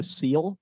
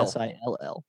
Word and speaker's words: sill: I've been calling it a window seal. – Yeah, that's wrong. But it's actually a window sill. S I L L.